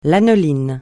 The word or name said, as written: lanoline